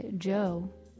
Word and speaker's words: Djo: End of the Beginning by Joe, spelled D-J-O, but pronounced just like Joe.